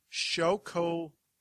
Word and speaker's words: Xocoveza: So it's pronounced Xocoveza. It's Xocoveza.